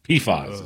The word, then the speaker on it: PFAS: PFAS is how they pronounce it.